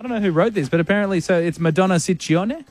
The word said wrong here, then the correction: Ciccone: Well, in Italian, it works the other way. So the double C's are hard C's and the singular C's are soft C's. – So Ciccone.